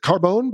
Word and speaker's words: Carbone: Yes, my last name is Carbon. That's a bit of French spelling, but anyway.